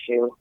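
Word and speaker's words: choux: good one for Shu.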